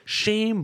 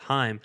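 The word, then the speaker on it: Chaim: Let's discuss Mike Hazen leaving for the Boston Red Sox, because the Red Sox just fired their GM, Shane Bloom, or Heim Bloom, I think it's pronounced. I always called him Shane, but I think it's pronounced Heim.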